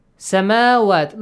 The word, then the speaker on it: سَمَاوَات: سَمَاوَات, look on the top of waw, you have a baby alif, which is called dagger alif, and you have to pronounce it as alif.